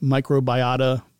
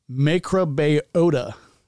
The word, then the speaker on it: microbiota: But I thought it would be like microbiota, but no, it's microbiota.